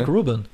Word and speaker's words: Rick Rubin: Rick Rubin.